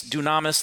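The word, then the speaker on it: dunamis (δύναμις): Now, if you check the root of that word, it comes from the Greek, which means dunamis. That's how you pronounce it, which means uh, dynamite or miracle power, wonder working power, power that is very uh, dynamic in its working.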